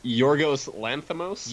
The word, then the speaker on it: Yorgos Lanthimos: It's Yorgos Lanthimos, I think.